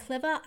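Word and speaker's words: Klevur: It's by Ori and Clever. I think I said it right. It's K-L-E-V-U-R.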